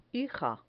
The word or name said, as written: hija